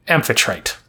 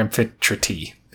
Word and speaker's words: Amphitrite: which is a water nymph am- Amphitrite, or Amphitrite, if the E is pronounced.